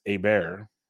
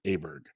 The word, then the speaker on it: Åberg: And over there, it's Åberg. But the reporter said, an acceptable U.S. pronunciation is Åberg.